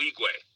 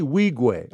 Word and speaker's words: Wigwe: No Uigwe. N. – Oh, the N is silent. – Yeah, Uigwe. – Toby Uigwe.